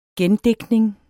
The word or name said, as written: gendigtning